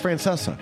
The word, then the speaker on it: Francesa: I'm killing it on the Francesca. – Shocking. – Yeah, he's drinking too much Francesca. It's actually pronounced Francesa.